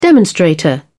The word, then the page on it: demonstrator: British English